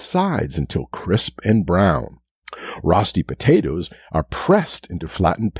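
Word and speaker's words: rösti: Today's word is rosti, spelt R-O-S-T-I, rosti, sometimes pronounced roasty.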